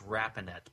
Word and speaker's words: Rapinet: It's pronounced Rapinet, but it's R-A-P-I-N-E-T.